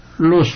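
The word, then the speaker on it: l'eau: Waterloo, L'EAO, y se pronuncia lo, significa el agua.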